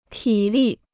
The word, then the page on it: 体力: tǐ lì